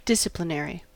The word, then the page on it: disciplinary: American English